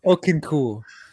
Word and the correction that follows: Okonkwo: I do know how to pronounce that name. It's Chigozim Okonkwo.